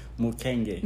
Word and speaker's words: Mukenge: How do I say your last name? – Mukenge. – Mukenge. – Yes.